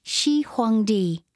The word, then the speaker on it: Shi Huangdi: The first of the Great Walls in the north of China was built in the Qin, pronounced Qin, Dynasty by the Emperor Shi Huangdi, pronounced Shi Huangdi, in about 200 BC.